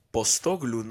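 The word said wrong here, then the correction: Postecoglou: no sé cómo se pronuncia. Ajá. Postecoglu.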